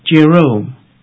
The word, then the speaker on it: Jerome: The first is spelled J-E-R-O-M-E. – And it is pronounced Jerome.